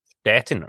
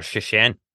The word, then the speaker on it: Szczecin: Stettin or Szczecin. – Szczecin. So he was playing with Szczecin. – They're the same city. One's the German pronunciation and one's the Polish.